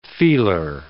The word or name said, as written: feeler